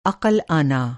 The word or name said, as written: अक़ल